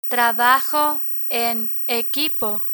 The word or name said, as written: trabajo en equipo